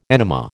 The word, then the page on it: enema: American English